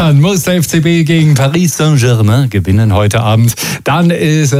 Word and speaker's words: Paris Saint-Germain: Ich bin ein Laufkind in der Champions League im Halbfinale gegen Paris Saint-Germain.